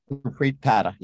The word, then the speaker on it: Gurpreet Padda: Gurpreet Padda, yeah.